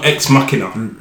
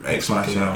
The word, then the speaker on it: Ex Machina: It came out the same year as Ex Machinima, right? – I think so, possibly. – I hope I'm saying that right. Ex Machinima, right? – Ex Machinima. I know Ex Machina. – Ex Machina.